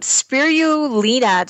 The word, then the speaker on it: spirulina: So this month, we're going to talk about spirulina.